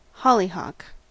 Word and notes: hollyhock: American English

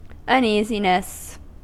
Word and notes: uneasiness: American English

